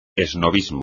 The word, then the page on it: esnobismo: espagnol